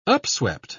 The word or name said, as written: upswept